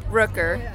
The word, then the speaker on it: Rooker: V-E-N-T-U-R-I-E-L-L-O. – My name is Anna Marie, A-N-N-A-M-A-R-I-E, Rooker, R-O-O-K-E-R. – Awesome. – Can I be? – Okay, my name is Jill, J-I-L-L, Delabovie, D-E-L-L-A-B-O-V-I-E.